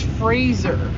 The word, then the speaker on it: Fraser: It's Fraser. – Well, that's disappointing to my mind. – I can't say it. It hurts Brendan Fraser. – That's going to be difficult. – It rhymes with Razor.